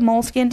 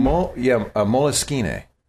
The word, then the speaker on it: Moleskine: Do you like the moleskin? Do you pronounce it moleskin? – Moleskine. Believe it or not.